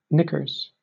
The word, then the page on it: knickers: American English